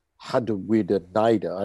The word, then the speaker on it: Harawira-Naera: Um, and uh, the Raiders were really good against the Sharks, in particular Corey Harawira-Naera, which I believe is pr- pronounced Hadawida Naira. I don't, I'm buggered if I know why, but um, that's how they're saying his name now.